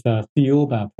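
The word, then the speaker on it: Thiaoouba: Well, the author, Michel Desmarquet, pronounces as Thaoba Prophecy. – The Thaoba Prophecy, okay. – Which is similar to the pronunciation of Jehovah, which has a connection to the Bible.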